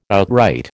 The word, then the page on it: outright: American English